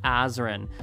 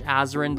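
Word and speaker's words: Azren: It is at Polyglot Azrin. That is spelled P-O-L-Y-G-L-O-T, A-Z or Z, depending on your country, R-E-N. You can also simply search Azrin the Language Nerd on whatever your favorite social network is.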